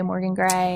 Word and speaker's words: Morgan Gray: Um, and it kind of works out that it rhymes with my name, like "Whisk me away," Morgan Gray.